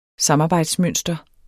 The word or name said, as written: samarbejdsmønster